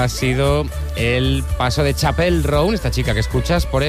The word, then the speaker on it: Chappell Roan: Ella misma nos explica cómo se pronuncia. – Chappell. Roan. Chappell. – Okay. Ah, pues lo digo mal yo.